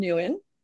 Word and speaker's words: Nguyen: So Viet Thanh Nguyen.